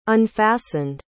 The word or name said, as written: unfastened